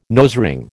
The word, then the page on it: nosering: American English